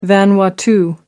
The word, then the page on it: vanuatu: American English